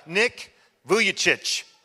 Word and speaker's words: Vujicic: We pronounced it multiple ways, but Nick Vujicic, right? That's it. Hey, all right. I'm like, is it soft CH? No, it's chich.